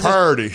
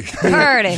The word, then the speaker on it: Purdy: I think how do you say it the way Cam says it? – Purdy. – Purdy. – Brock Purdy. – I think everyone has taken Sound like you say a parody.